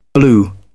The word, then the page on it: blew: British English